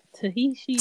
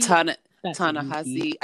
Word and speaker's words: Ta-Nehisi: Tahishi? – Ta-Nehisi.